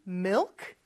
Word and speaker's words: milk: Milk 발음이 조금 어려울 수 있어요. Mill 하면서 을 발음할 때는 혀가 윗니 뒤쪽에 와서 쫙 붙지만 뒤쪽, 혀의 뒤쪽은 목구멍을 약간 닫는 느낌을 주죠. 그러면서 크 살짝 해주는 거예요.